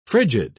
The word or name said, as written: frigid